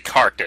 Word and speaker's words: carked: Oh, and it's actually carked it, not cracked it. – What? What ain't carked? It's a cracked. – It's a C-A-R-K-E-D. Carked.